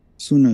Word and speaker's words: Sunnah: Am I saying that right? Sunnah? – Sunnah is right. Yeah, Sunnah.